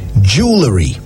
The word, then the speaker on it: jewellery: Jewelry.